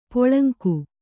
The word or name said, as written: ಪೊಳಂಕು